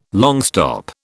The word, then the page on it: longstop: British English